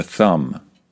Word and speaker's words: thumb: Let's start off with the most obvious one, the thumb. Be careful with the pronunciation here. It is a silent B at the end of this word, thumb.